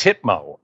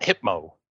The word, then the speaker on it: Hypno: It's Hypno, actually. – Oh, Hypno-Chan or Hitman? Yeah. Or Hypno... – Hypno. – Oh, Hypno. Hypno. Okay. – Hypno, not Hypmo.